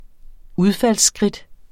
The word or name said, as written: udfaldsskridt